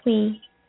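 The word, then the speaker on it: Thuy: My name is Twee. – Twee? Can you spell that for me? – Yeah. It's T-H-U-Y, as in yesterday, but it sounds like T-W-E-E.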